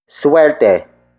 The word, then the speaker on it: suwerte: Suerte. Suerte. Suerte is spelled as S-U-W-E-R-T-E.